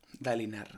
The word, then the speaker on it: Dalinar: Como, ahora voy a entrar en un nicho otra vez, es como más llevar una vida de eh, Danilar, ¿no? Dalinar. – Dalinar, sí. Y lo he dicho mal.